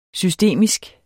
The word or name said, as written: systemisk